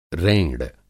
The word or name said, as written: रेंड़